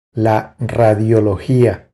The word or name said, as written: La radiologia